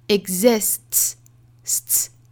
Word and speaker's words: exists: 这个词本来应该是读成 exist exist，现在呢我们就把 t 的发音给抹掉了，替换成一个 t 的发音就变成了 exists sts ts，